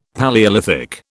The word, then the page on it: Palaeolithic: British English